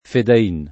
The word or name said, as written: fedain